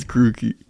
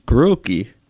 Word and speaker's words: Grookey: What about Grookey? – It's Grookey. – Grookey.